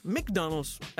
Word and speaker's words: McDonald's: McDonald's. McDonald's er faktisk den rigtige udtale, jeg er ret sikker på mig i.